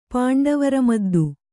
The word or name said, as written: ಪಾಂಡವರಮದ್ದು